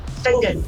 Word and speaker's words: Finzean: Please spell F-I-N-Z-E-A-N. Benzean. Pronounced Fingen.